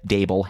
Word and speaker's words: Daboll: I don't know if you noticed, but I routinely say his name wrong. Brian Daboll, head coach of the New York football giants. He's making chicken salad out of chicken shit. I said it right this week, guys.